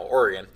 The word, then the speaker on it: Oregon: We've been in all kinds of different states, from Oklahoma to New Mexico to Wisconsin, Indiana, Illinois, and now Oregon. I don't say Oregon. You didn't say Oregon. You said it right that time.